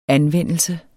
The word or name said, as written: anvendelse